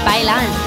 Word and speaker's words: bailan: Bailan. – Bailan? – Bailan, baila, baila. – Like it just rhymes with pylon? – No, like bailan, like the Spanish word for dance.